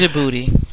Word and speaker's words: Djibouti: It's Djibouti. – Djibouti. – Because you don't pronounce the D.